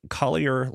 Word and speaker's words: Collier: Uh, I was on TikTok a few weeks ago, and I ran across uh, a guy named Collier Landry.